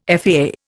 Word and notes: FAA: Indian

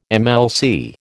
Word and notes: MLC: American English

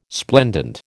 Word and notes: splendent: American English

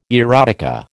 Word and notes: erotica: American English

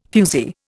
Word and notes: fuzee: Indian

